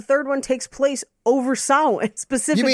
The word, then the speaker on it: Samhain: Samhain? – Yes, Samhain.